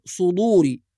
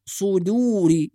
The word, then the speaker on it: صدوري: أيضا في كلمة صدوري، بعض الناس يفخم الدال فيقول صدوري، وإنما هي دال وليست ضاد، صدوري، يبقى لازم نحافظ على ترقيق الدال.